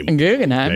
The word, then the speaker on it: Guggenheim: Say it again. – The Guggenheim? – Guggenheim. Yeah, you say it like that.